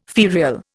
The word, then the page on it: ferial: Indian